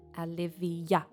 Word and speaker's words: Aliviyah: Olivia, lots of people, Olivia, usually spelt with an O. This is spelt A-L-I-V-I-Y-A-H. Olivia.